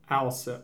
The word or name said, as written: Alsip